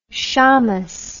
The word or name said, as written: shamas